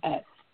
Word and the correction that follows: melodious: Do you remember how you pronounce that word? – Melodious. – Melodious, thank you.